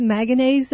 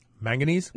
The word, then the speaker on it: manganese: It has manganese in it. – Manganese? – Manganese. I always say that wrong.